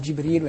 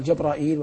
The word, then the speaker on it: جبريل: وقد ورد في نطقه 10 أقوال. نعم. يعني في الاسم. نعم. يقال جبريل ويقال جبرائيل ويقال جبرائيل وكلها واسعة.